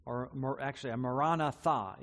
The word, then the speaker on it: Maranatha: And then he adds this little phrase, Maranatha, Maranatha is probably the better way to pronounce that.